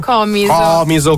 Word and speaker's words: Comiso: Comiso con l'accento sulla prima O.